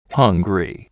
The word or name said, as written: hungry